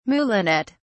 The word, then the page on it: moulinet: British English